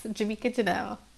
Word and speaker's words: Jamiqua Janelle: Yes, Jamiqua Janelle.